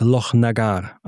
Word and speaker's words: Lochnagar: Ja, also das Loch ist auf jeden Fall schon mal richtig. Du wirst lachen, auch das machen viele falsch. Es ist definitiv nicht Loch, weil das ist immer ganz lustig mit den Engländern, die das nicht sprechen können. Es ist Lochnagar, also die Betonung liegt ein bisschen auf dem Agar.